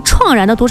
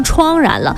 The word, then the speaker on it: 怆然: 啊，有一个阶段，可能很多朋友可能念错错别字了啊，把那个怆然的读成怆然了，没有这个音哈，是怆然，就是非常的悲戚，悲从中来啊。